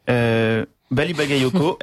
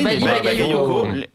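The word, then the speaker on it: Bally Bagayoko: Bali Bagayoko, elle est fille. – Bravo. Tu l'as bien prononcé. – Bah oui, Bali Bagayoko, maire de Saint-Denis, et qui, en plus de ça, enfin.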